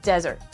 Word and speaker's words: Dessert: So it's spelled like dessert, but it's pronounced Desert.